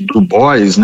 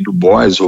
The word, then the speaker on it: Du Bois: Du Bois, né? Algumas pessoas falam W.E.B. Du Bois, ou falam com a pronúncia mais afrancesada, que é o Dubois, William Edward Burrell Du Bois, ou Du Bois, que era a NAACP, National Association of Advanced of Colored People.